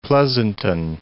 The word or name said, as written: Pleasanton